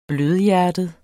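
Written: BLØDHJERTET

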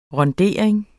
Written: RUNDERING

